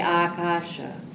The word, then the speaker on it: Akasha: So this long A in the front, because the Jaya ends with an A, it blends with that. So it's Om Jaya Akasha.